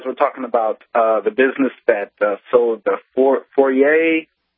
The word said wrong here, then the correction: foie gras: you know, the ducks that are force-fed. – Foie gras. – Okay. – Oh, yes. Foie gras. Thank you. I don't know how to say it.